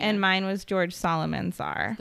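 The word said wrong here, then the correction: Psalmanazar: The silent P. – Salmanazar, sorry. – Salmanazar, yeah.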